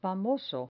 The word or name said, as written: famoso